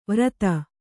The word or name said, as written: ವ್ರತ